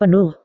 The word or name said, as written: penuh